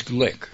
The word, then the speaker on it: Glück: Most celebrated American poet, Lousie Glück. Name known around here. Rhymes with click.